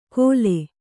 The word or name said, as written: ಗೊಲೆ